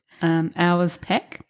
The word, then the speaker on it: Auersperg: And then trying to spell my name for someone, it's just – Yeah, it's tricky and it's pronounced – Auersperg.